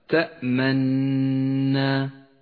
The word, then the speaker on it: تأمنا: الإشمام هو ضم الشفتين بدون صوت بعيد إسكان الحرف، سمي إشماما لأننا نشم الحرف حركة الضم إشماما ولا ننطق بالحركة. هكذا تأمنا، تحرك الشفاه بالضم عند نطق حرف النون من غير أن نلفظ الضمة، وهذا ما يسمى الإشمام.